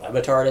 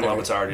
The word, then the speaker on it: Le Batard: It's called Highly Questionable with Dan Le Batard, I think.